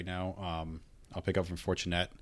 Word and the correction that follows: Fournette: You gotta say his name right. – Leonard Fournette. – Okay, so Leonard Fournette, this is the 15th overall, then Dez Bryant, T.Y.